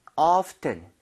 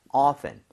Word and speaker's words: often: 자, 얘는요 발음이 두 가지입니다. 하나는 often 해서 T 사운드를 내는 거, 또 하나는 T를 묵음 처리해서 그냥 often. 아마도 요즘에는 often이라고 발음하시는 분들 더 많을 거예요. 근데 그거는 뭐 그냥 personal choice일 뿐입니다.